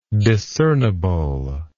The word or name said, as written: discernible